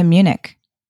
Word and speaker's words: Munich: Is it Munich? Am I pronouncing it right? – Yes. Okay. München. – Sagen oh. – We say, yes, München.